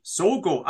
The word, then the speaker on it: Sogo: Sogo, am I saying that right? – Yeah, sounds just about right, yeah.